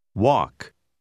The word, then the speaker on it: walk: La lettera L non si pronuncia. – Walk.